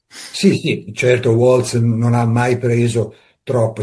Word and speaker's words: Waltz: Kenneth Waltz, o Waltz, come lei dice, pronuncia, io lo faccio alla tedesca perché è di origine tedesca il nome, secondo me.